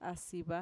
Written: asiva

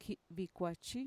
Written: ki'vikuachi

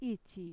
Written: itxi

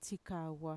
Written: txikagua

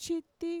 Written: chiti

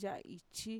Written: ya'aichi

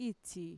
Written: itxi

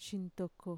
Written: shintoko

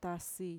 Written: tasi

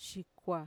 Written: shikua

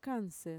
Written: kanser

